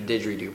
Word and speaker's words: didgeridoo: It was like another show, the didgeridoo portion of the show.